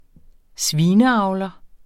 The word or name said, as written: svineavler